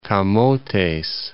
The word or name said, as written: Camotes Sea